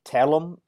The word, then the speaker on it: tselem: I think it's in the doctrine of Imago Dei, you get into this idea of telem, I think is the way you say it. It's spelled T-S-E-L-E-M.